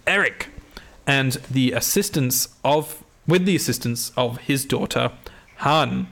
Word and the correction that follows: Hannah: Hannah, I think it is.